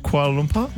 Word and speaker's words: Kuala Lumpur: Is it, Sarah, is it Kuala Lumpur? – Yeah, you said it right. – Kuala Lumpur? – Yep.